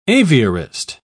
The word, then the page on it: aviarist: British English